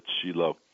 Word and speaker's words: Shiloh: In Israel we call it Shiloh. That's the Hebrew pronunciation.